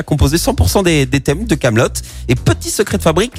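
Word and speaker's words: Kaamelott: Et en point info, Camelot s'écrit avec euh, deux A en référence à ses initiales, A et il a ajouté un T à la fin, juste pour être sûr qu'on prononce bien Hot et pas euh, Camelot.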